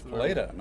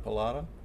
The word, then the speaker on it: Paleta: Yes, it's Paleta, not Palata.